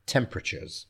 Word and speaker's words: temperatures: Temperatures, okay, so we're not saying temperatures, temperatures. Again, we're pushing the last two syllables together, temperatures, temperatures. Okay, so in the next 10 years, average temperatures will probably go up slightly, slightly.